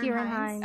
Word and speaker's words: Ciarán Hinds: Ciarán, Ciarán Hinds.